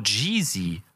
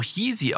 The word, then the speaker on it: Yeezy: Warum sagt sie zu Yeezy so Jeezy? Das wird doch Yeezy ausgesprochen.